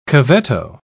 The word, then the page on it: cavetto: British English